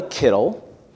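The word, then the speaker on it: kittel (קיטל): That's spelled K-I-T-T-E-L, kittel.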